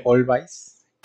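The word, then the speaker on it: Alvvays: se escribe Always con W, pero se pronuncia Always.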